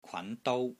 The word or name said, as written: KWAN2 DOU1